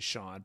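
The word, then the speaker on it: Shawon: It's Sean, but it's S-H-A-W-O-N. And to be fair, David, I think that Harry Carey always had an extra long Sean in there, that which I always had read, it always read to me as being almost a second syllable, if not a full second syllable. But in the interest of accuracy, we should make clear that it is actually Sean.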